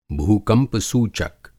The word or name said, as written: भू-कंपसूचक